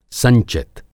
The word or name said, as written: संचित